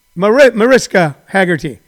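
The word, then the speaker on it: Mariska Hargitay: She goes on about, and I always pronounce her name wrong. Is it Margista Haggerty, Cheryl? Mariska Haggerty,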